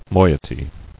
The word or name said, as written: Moieties